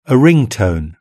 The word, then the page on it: a ring tone: British English